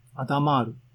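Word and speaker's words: Hadamard: で、えっ、ー、と、 PyTorch のドキュメントには、このアスタリスクはアダマール積である。アダマール。多分ね、えー、の、フランスの方なんですよね。 H は発音しないし、 D も発音しないんですけども、どうでもいいけども。アダマール席、なんかね、わかりにくい名前がついとるな、って言うんですけども、ウィキペディア行ってください。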